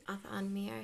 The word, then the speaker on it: Atha'an Miere: Athanmir? Did I say that right? Atha'an Miere, yeah.